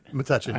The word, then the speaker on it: Metuchen: So, and you know, Sharon lives in Metuchen. Is that how you say it? – Metuchen, I always get it wrong.